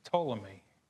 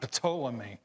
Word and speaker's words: Ptolemy: This is the one I practiced the most because they said the P is silent. It's Ptolemy. And everybody's going, yeah. Everybody that knows this is, you did that right. Because when I first pronounced it, I said Ptolemy.